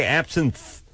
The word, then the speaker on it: absinthe: It's spelled like absinthe. – Yeah. – Like you're absent from school, but they say absinthe. – Well, T-H-E at the end, absinthe. – I didn't see a T-H-E at the end.